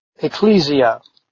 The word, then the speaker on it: ecclesia: By the way, the Greek pronunciation is ekklesia. The Latin pronunciation is ecclesia with the double C instead of the double K.